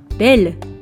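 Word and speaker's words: belle: 男性形は、ボ、女性形は、ベル、ベルとなります。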